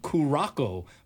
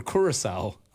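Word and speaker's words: Curaçao: And recently on ESPN Radio, a stand-in guy for Father's Day said Kurako for Curacao.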